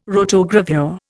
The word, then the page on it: rotogravure: Indian